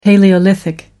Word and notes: palaeolithic: American English

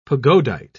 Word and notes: pagodite: British English

